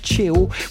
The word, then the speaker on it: Cheo: Je vous propose un artiste qui s'appelle Cheo. Vous l'avez peut-être connu comme membre fondateur de l'excellent groupe funky vénézuélien Los Amigos Invisibles. Et je regarde Alejandro qui me fait un oui de... Oui, j'ai prononcé correctement.